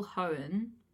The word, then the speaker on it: Hoen: Yeah, I'm really hoping I'm pronouncing that right, because that's the only way I could think. – How is it spelled? Is it spelled like Gen 3 Pokemon? – H-O-E-N? – Yes, so...